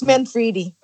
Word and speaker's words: Manfredi: Lindsay, how do I say your last name? Is it Manfredi? – It's Manfredi. – Manfredi, perfect. Yep. You say it so much better than I did.